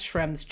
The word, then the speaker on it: Schrems: It's SCHREMS.